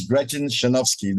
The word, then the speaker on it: Gretchen Shanofsky: Today, we have a wonderful guest, and her name is Gretchen Shanofsky.